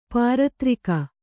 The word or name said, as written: ಪಾರದರಿಗೆ